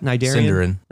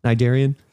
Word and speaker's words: Sindarin: Sindarin. – Yeah. – Is that how you say that? Nidarian. – Sindarin.